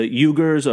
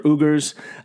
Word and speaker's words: Uyghurs: and I believe it's pronounced the the Uyghurs or Uyghurs. Um, it's U Y G H U R S, and and they are China uh, a Chinese um, Muslim population uh, that are being sent into concentration camps.